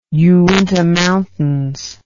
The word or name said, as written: Uinta Mountains